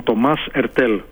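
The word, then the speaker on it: Thomas Heurtel: Τώρα η ΑΕΚ, όπως είπες, έκανε μια μεταγραφή σημαντική, σημαντικότατη, καθώς πρόκειται για ένα παίκτη ευρωλιγκάτο, τον Τομάς Ερτέλ, όπως το προφέρει ο ίδιος, είναι Γάλλος διεθνής.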